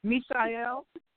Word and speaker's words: MeSha'el: Can you say it for me one more time? – Mishael. – Okay, so I am saying it right to people. People correct me. Okay. Mishael, yes.